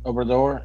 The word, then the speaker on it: Obrador: And was it Obrador was the leader, I think? – Yeah. Now the president. – Did I pronounce that correctly? – Obrador, yeah.